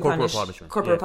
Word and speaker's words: corporal: To make like corporeal punishment, how do you say it? To physically corporal punishment.